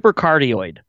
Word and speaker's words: cardioid: Cardinoid, I believe. – There you go. – Super cardinoid, yeah. – Carteroided? It's a carterized? – Super cardioid.